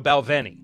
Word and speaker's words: Balvenie: Is this one pronounced with a W or a V? I can't remember. I've heard it both ways, and I'm not just saying that because it's a reference to one of my favorite shows of all time. If you get it. What up? But it is a V. So Balvenie.